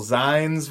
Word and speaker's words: zines: You're making really cool zines, which are they called zines or zines? – Zines. – Zines. God, oh Zines. – wow, I sound like an idiot. I've been saying that wrong for years. That makes sense because they're like magazines.